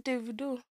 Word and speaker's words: Davido: So I was like, no, it's pronounced Davido. That because he's a Nigerian artist, how he says his name is the correct way to say his name.